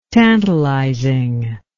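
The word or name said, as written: tantalum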